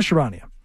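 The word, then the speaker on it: Charania: Shams, how do you pronounce Shams' last name? Sharania? Yeah, Sham Sharania.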